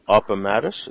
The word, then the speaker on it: Appomattox: And these three pieces, Marie's Letters, Sound Off, and then is it Appomattox?